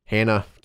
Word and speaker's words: Hana: and Hana. Hannah, keep forgetting she does the English pronunciation of her name.